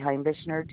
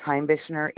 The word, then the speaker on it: Heimbichner: Yep, my zip code is 59102, and my name is Lori Heimbichner. Do you need me to spell that? – Yes, ma'am. – Okay, Lori is L-A-U-R-I-E. Last name is Heimbichner, H-E-I-M-B-I-C-H-N-E-R.